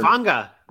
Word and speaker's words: Hufanga: Hopefully some of these young guys can step up in a Womack or I'm going to say butcher his name, Talanoa Hufanga or whatever. – Hufanga.